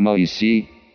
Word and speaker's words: Моисей: Например, в Коране его называют Муса, а для русского перевода Торы, то есть Ветхого Завета, было выбрано имя Моисей с ударением на последний слог, то есть имя из Торы было адаптировано для славянских народов.